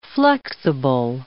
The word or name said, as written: flexible